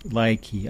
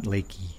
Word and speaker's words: Laiki: Lakey, L-A-I-K-I. I guess you can pronounce it Likey or Lakey. Likey. I like these crackers, but I prefer to pronounce it Lakey. Do they have a pronunciation? They do have a pronunciation. L-Y hyphen K-E-Y. Likey. Likey. Okay, so it's supposed to be Likey, not Lakey.